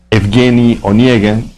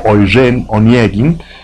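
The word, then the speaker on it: Evgeny Onegin (Евгений Онегин): Estimados amigos, es un gusto que nos acompañen en esta charla introductoria a Eugenio Onegin, Evgeny Onegin, según la pronunciación rusa, una ópera de Piotr Ilich Tchaikovsky, que frecuentemente es pronunciada en francés como Eugène Onegin,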